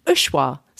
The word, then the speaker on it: UXUA: In terms of hotels that have used style and design to have an incredible impact on their local communities, Ushua, so it's spelled UXUA.